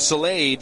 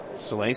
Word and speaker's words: Sale: Duran still standing on third base now with 2 outs as Josh Soleil digs in. Soleil spelled like sail, S-A-L-E.